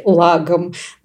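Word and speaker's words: lagom: И вот лагом более сложное для меня слово, потому что я всегда забываю, куда там правильно ставить ударение. И я специально перед подкастом погуглила, мне интернет сказал мне все-таки лагом на первый слог.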